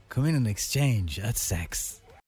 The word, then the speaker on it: CEX: That's pronounced sex. – Yeah, all right, well, that's how you spell it.